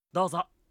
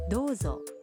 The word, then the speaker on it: どうぞ: 도저.